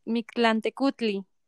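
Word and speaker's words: Mictlantecuhtli: ¿Y Micantecutli? ¿Lo pronuncié bien? – Mic Mictlantecutli.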